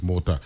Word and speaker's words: Motta: A lot of people say it. They say, like, they know Jake LaMotta. And they'll say Mata, but it's Mota.